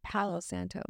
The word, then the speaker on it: Palo Santo: Palo Santo.